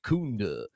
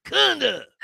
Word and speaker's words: Kunda: And I understand that because most people, including Sylvester Stallone, say my last name wrong. – Right. – And it's Mike Kunda. – Yeah. – It's not an ooh. – It's a It's like Clever Lang. Come on, Balboa. Kunda.